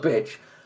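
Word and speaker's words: Bitsch: It's B-I-T-S-C-H. How would you pronounce that? – No, I think you're right. It's Charles Bitch.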